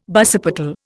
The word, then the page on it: basipetal: Indian